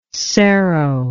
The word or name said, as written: cerro